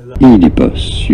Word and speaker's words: Oedipus: I just want to let you know that Oedipus. You do want to stress on the first syllable.